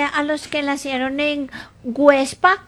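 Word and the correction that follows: Güepsa: Perdón, Huepsa. Websa, ¿ah? Con diéresis.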